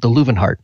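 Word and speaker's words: Loewenhardt: And I didn't know this, that the W is pronounced as a V. And so it's the Lowenhart.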